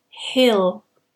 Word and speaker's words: hill: we say hill, hill. So the E sound is much shorter.